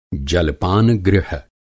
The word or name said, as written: जलपान-गृह